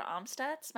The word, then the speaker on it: Amstutz: All of my social handles are at Sandra Amstutz. My last name is spelled A-M-S-T-U-T-Z.